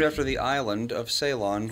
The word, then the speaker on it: Ceylon: It is Ceylon. – How do you spell it? Oh, C-E-Y-L-O-N?